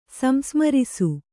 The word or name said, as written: ಸಂಸ್ಮರಿಸು